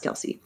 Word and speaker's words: Kelce: It was Travis Kelce for 10 points.